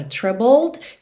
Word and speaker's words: troubled: Então, esse sentimento de aflição, quando você está se sentindo assim, você pode dizer então que você está troubled. Ok? Atenção à pronúncia dessa palavra troubled, que a gente escreve T-R-O-U-B-L-E-D, mas você não pronuncia esse E.